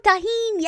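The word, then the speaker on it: Tajín: Not tahine? – Yeah, tahine. – Honey. – Spray. – I've put honey. No. Oh, tahine, yes.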